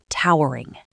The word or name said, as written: towering